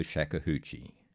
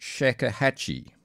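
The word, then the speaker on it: shakuhachi: or Grandmaster of the Shakuhachie. Sorry, I'm mispronouncing that. Shakuhachie.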